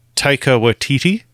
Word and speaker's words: Taika Waititi: You can't go wrong with a comedic role of Taika Waititi.